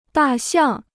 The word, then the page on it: 大象: dà xiàng